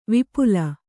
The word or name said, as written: ವಿಪುಲೆ